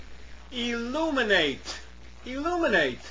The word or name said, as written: illuminate